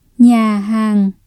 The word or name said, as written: nhà hàng